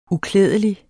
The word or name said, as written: uklædelig